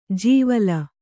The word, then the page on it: ಜೀವಲ: Kannada